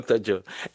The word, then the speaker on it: Tadjo: So, Barani, so that people who are like listening, your last name is spelled T-A-D-J-O, Tajo, right? – Yes, Tajo. – Okay, oh, Tajo.